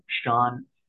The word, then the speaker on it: Sean: I'm on Instagram, Daniel Sean Smith. That's Daniel, and then Sean is spelled S-E-S-E-A-N, Smith. And also, I have a YouTube channel that I'm trying to grow. It's called, it's my name, Daniel Sean Smith.